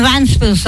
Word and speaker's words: Ventspils: Kā tad jāizrunā īsti? Plato vai Šauro? – Nav nekāds Šaurais E. Ir Venta un Ventspils.